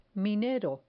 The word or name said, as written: minero